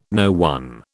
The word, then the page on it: no one: British English